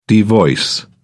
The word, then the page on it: devoice: British English